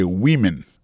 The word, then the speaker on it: women: Mais tandis que les mots femme dans au pluriel, qu'on a pas prononcé woman, donc c'est women, many women.